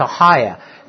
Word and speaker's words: Achaia: And when I heard the Greek pronunciation, it's Achaia.